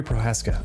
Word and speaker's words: Prochazka: Yuri Prochazka.